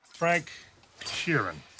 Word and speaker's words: Frank Sheeran: Frank Sheeran, I saying that right? Yeah, you said right.